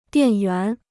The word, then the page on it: 电源: diàn yuán